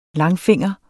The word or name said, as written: langfinger